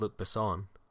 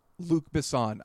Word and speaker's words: Besson: I always thought of it as Luc Besson. – Luc Besson.